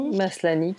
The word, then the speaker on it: Maslany: Maslany, qui joue Sœur Alice, qui est donc la prêcheuse, est démentielle dans la série.